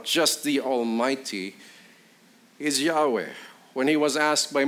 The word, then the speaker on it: Yahweh: When you see capital L O R D in your Bibles, it is the proper name of Yahweh. Y H W H. Others pronounce it Yewah. The Germans pronounce it, pronounce it Jehovah, but it is the name that God introduced himself.